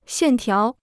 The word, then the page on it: 线条: xiàn tiáo